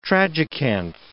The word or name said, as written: tragacanth